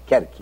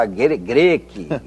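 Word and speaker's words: Cherchi: E eu inventei, acabei inventando esse nome, porque a família da minha mãe, é, de italianos lá da Sardenha, eram os Kerkes, Kerki, né? é C-H-E-R-C-H-I, Kerki. – Né? Sim. Né? – Mas a minha, a minha família todo mundo pronunciava Greque, Greque, Kerki.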